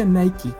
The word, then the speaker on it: Nike: Para Nike, precisamente se pronuncia Nike. – Pues depende. Ya ves que Adriana, de Castro siempre decía que se pronunciaba Nike y nadie le hizo caso nunca. – Así se pronuncia, pero pues.